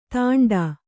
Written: ತಾಂಡಾ

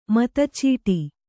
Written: ಮತಚೀಟಿ

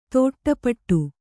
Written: ತಾಟಿಪೋಟಿ

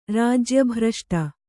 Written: ರಾಜ್ಯಭ್ರಷ್ಟ

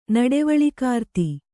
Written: ನಡೆವಳಿಕಾರ್ತಿ

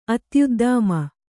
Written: ಅತ್ಯುದ್ದಾಮ

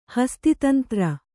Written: ಹಸ್ತಿತಂತ್ರ

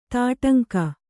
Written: ತೊಡಂಗ

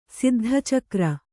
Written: ಸಿದ್ಧಚಕ್ರ

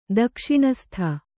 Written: ದಕ್ಷಿಣಸ್ಥ

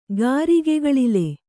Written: ಗಾರಿಗೆಕಳಲೆ

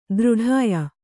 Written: ದೃಢಾಯ

